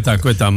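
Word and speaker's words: Ceuta: Queta, hoe spreek je dat? – Queta, madame Queta. – Queta, ja.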